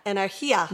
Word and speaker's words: energía: Yeah, I know, but how do we say that word? – Energía. – Energía. That's right.